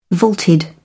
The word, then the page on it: vaulted: British English